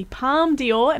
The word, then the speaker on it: Palme d'Or: This is Movies, Movies, Movies, and up next we're talking to actor, writer, and performer Mandela Matia about starring in the Palme d'Or, am I saying that right? – Yeah.